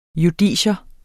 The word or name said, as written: JUDICIETS